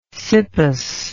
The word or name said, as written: cippus